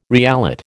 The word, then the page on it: reallot: American English